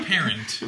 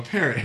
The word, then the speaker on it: parrot: Well, not a parent. – No, a parrot. – A parrot. – A parrot in Michigan. – Rhymes with carrot.